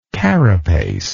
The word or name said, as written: carapacial